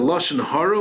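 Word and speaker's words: lashon hara (לשון הרע): the Lashon Hara, Afilu El Emes.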